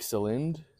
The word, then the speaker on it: Celinde: It's like C-L-I-N-D-E. – C-L-I what? – C-E-L-I-N-D-E. – I don't know. – Like Celind? – Celind, yeah. – Celind, like Schoenmaker. S-C-H-O-E-N-M-A-K-E-R. – Oh, sounds German, yeah. – She's Dutch. – There you go. – Celind Schoenmaker, if that is your real name.